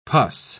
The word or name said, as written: pus